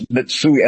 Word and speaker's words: Mitsui: Mitsu Trust, the Mitsubishi Trust, and other conglomerates. – I think that would be pronounced, one more, I think that's Mitsui, M-I-T-S-U-I, is that correct? – Correct.